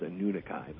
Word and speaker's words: Anunnaki: So anyway, the modern pronunciation of the word, as far as I know, is Anunnaki, but yours is just as good.